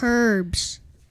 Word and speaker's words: herbs: with herbs. – The H is silent.